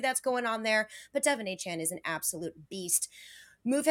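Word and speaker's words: De'Von Achane: It's Devon Achane. I said it right. Yes. – You did. Me. I know. – It's Devon Achane.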